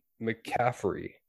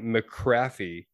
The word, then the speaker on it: McCaffrey: But there's this guy at the lounge, 49ers fan, local team, and he was mispronouncing McCaffrey. And if you don't know who McCaffrey is, you don't watch football. He was saying McCraffy, and he was super loud and obnoxious about it.